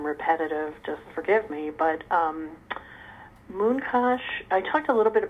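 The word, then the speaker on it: Munkács: And the place he was from in Hungary was called Mooncash, although it's spelled differently in Hungarian. – That's right. – I spelled it phonetically, because that's how I always heard it.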